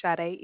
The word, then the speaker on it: Sade: My name is Sade.